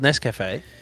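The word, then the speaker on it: Nescafé: Freeze-dried Nescaf. Blend 43, baby. – Why you say Nescaf? – What do you mean? – It's called Nescafe.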